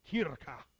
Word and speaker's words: Kirche: So I went to George, and I said, George, I need you to pronounce this word for me in your best German accent. And he went, it's Kirche. A lot of, you know, deep throat stuff.